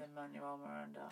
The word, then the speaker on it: Lin-Manuel Miranda: Lin-Manuel Miranda.